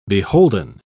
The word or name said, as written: beholden